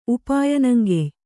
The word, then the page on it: ಉಪಾಯನಂಗೆಯ್: Kannada